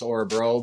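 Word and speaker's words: Örebro: They came back, and they just came up a little short there against Orebro, but I, I thought they could have won that that series, and then they get to the semifinals. You never know. – I love your pronunciation of Orebro.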